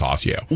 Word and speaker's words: Osseo: And it's still pronounced Osseo.